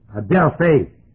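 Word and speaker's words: Adelphe (ἀδελφή): Adelphe, A-D-E-P-H, or A-P-H. Adelphe, A-D-E-P-H, long E, Eta, instead of Epsilon.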